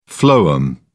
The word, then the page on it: phloem: British English